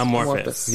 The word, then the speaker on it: Amorphous: Amorphous.